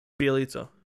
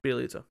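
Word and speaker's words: Bjelica: Bilagica? How do you pronounce his name? I don't even know. – Bielitsa. – Yeah, that guy. – Bielitsa.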